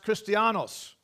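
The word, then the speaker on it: Christianos (Χριστιανός): And in the Greek language, it's pronounced Christianos.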